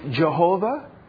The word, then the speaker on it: Jehovah: And so, in some circles, it's pronounced Jehovah. And some people pronounce it, what's the other pronunciation? Do you know it? Yeah. Yahweh.